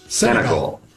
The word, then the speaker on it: Senegal: They basically needed a miracle to rally beat how do you say that word? Senegal? – Senegal. – Okay, that goal. There you go.